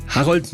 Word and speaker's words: Harold: Harold mit O und nicht mit A, wie es normalerweise hier in Deutschland üblich wäre.